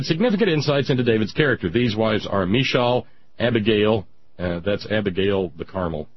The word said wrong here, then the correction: Michal: Michal. Thank you, Betsy. That's a silent C.